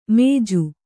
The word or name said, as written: ಮೇಜು